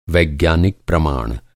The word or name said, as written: प्रमाण